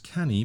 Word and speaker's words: Canu: And our sponsor today is Canu Publishing, which is spelt C-A-N-U, so looks like can you, but pronounced canny because it's a Welsh word.